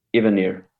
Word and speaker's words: Evanier: Evanier.